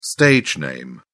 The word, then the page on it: stage name: British English